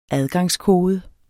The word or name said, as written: adgangskode